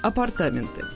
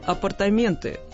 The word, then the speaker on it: апартаменты: Итак, есть еще люди, их немного, но они есть, которые говорят именно так. Апартаменты. Как правило, это люди старшего поколения, в основном из актерской среды, или старые радищики. Действительно, их так учили произносить. Правда, последний словарь ударений даже и не упоминает столь странное для нас ударение. Там только один вариант. Апартаменты. То есть так, как все мы привыкли говорить.